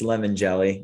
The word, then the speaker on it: Limongelli: well, if you want to Americanize it, it's lemon jelly, which was the most of my life.